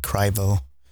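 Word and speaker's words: Crivo: it's Cry, like Cryvo. People pronounce it Cree, but it's because I have an I in it. I'll probably change it to a Y one day.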